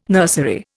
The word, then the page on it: nursery: Indian